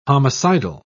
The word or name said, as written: homicidal